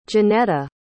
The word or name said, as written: jonetta